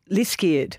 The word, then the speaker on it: Liskeard: It's Light Over, how did you pronounce it? – Liskeard. – Liskeard. – Well, that's the way my mum pronounced it when we went there.